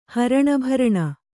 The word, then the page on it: ಹರಣಭರಣ: haraṇa bharaṇa; kannada